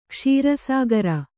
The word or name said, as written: ಕ್ಷೀರಸಾಗರ